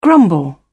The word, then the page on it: grumble: British English